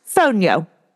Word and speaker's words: fonio: About Fonio. – Pronounced Fonio. – Oh, really? – I have since learned.